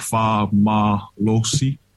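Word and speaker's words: fa'amalosi: So it's pronounced like fa malosi. – Fa malosi.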